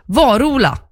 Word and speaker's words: Varola: Varola. Nu har jag lärt mig. Tänk på en varig person som heter Ola.